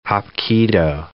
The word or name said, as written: hapkido